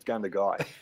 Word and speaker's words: Gundagai: No one lives in Gundagai, mate. It's Gundagai.